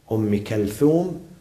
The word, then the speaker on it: Umm Kulthum: Then by Lady Zainab, which I am going to speak about her tomorrow, and then by Lady Umm Kulthum or Kalthum, this is the proper pronunciation, Umm Kalthum, which is I am going to talk about her the night on Wednesday, inshallah, the night after tomorrow.